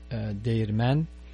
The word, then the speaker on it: Değirmen: Shvarman, in Turkish, that will be pronounced Değirmen. Quite a difference between, but it comes from the same root. The word comes from the same root, but it's quite a different pronunciation there.